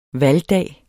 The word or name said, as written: valgdag